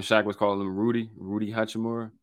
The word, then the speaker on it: Hachimura: So LeBron came out hot, started hot, and Uchi, what's his name? Uchi, how do I pronounce it before I say it wrong? – Hachimura. – Hachi, so Uchi. Uchiwali. No, Hachimura.